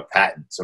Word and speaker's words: patent: So those are the two biggest issues when it comes to our patents, or if you are in the UK, patents. – What is that in the UK? – It's called, they call it patent. It's spelled the same way. – Oh. – US, it's patent. You in the UK, patent. – Oh, okay. In the UK, it's patent.